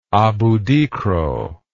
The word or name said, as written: aboudikro